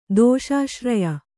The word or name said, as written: ದೋಷಾಶ್ರಯ